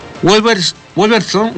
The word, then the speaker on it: Wolverhampton: El atacante mexicano volverá a Wolverhampton Wanderers. Así se pronuncia, aquí estamos.